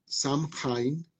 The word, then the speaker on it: Samhain: Eso se escribe Samhain, como Sam con h ¿cierto? Y se dice algo así como Samhain.